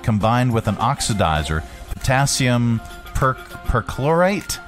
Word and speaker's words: perchlorate: How to pronounce percolate? – Perchlorate. – Oh, it's our pronouncify lady here. – Perchlorate. – Okay, perchlorate. – Now it makes sense. Perchlorate. – I'm learning. – Perchlorate. – Yeah. It's perchlorate.